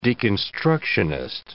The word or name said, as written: deconstructionist